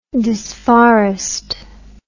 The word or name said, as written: disforestation